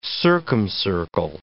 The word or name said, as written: circumcircle